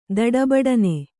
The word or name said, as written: ತಡಬಡನೆ